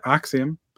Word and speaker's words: Axiom: We got Rhea Ripley, Naomi, Grayson Waller, Seth frickin' Rollins, Axiom. Did I say that right? – Yeah, Axiom.